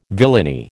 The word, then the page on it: villainy: American English